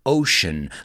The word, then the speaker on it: ocean: Ocean. Let's pronounce that word together. Ocean. Because that C sounds like an SH.